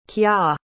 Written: kya

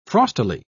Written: frostily

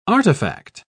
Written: artifact

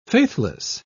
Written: faithless

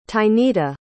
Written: tynita

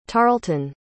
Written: tarleton

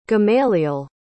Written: gamaliel

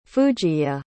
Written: fujiye

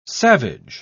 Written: savage

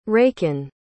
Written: Rakin

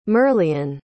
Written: merlion

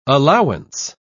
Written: allowance

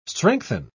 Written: strengthen

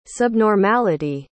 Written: subnormality

